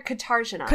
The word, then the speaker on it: Katarzyna: Is it Katarzyna or Katarzyna? – Katarzyna. I wrote the note that it's an emphasis in the last syllable. Katarzyna. So it's like Jenna, basically.